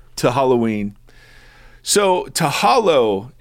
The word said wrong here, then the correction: hallow: hallow, I got to say it right, not hollow.